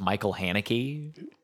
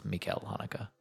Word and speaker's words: Michael: I thought it was Michael Haneke, and then I've been watching videos on him, and everyone's like, it's Mikel Haneke.